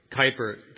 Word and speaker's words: Kuyper: Is it Kuyper or Cooper? Kuyper. Thank you.